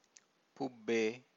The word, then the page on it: pubbe: Pali